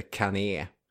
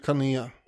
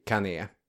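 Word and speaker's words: Cannae: Vi ska prata om kanai, eller kané. Vad har vi för uttal där egentligen? – Ja, jag säger nog kané. – Mm, då kör vi kané.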